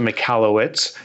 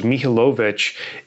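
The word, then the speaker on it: Michalowicz: You actually did. It's actually beautiful with the British accent. So I pronounce it McAllowitz, like you said. The original pronunciation, I believe, was Mihailovich. It's from Eastern Europe, Ukrainian, Russian area.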